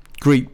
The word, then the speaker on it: Greet: Greet, but spelt like Crete but with a G.